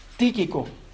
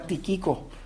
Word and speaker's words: Tíquico: Aquella la envió con Epafrodito y esta, en el capítulo 6, en el versículo 20, nos dice que la envía con Tíquico, que es un nombre raro, Tíquico. Yo pensaba que era tíquico, pero tiene acento en la primera y Tíquico, envía con Tíquico.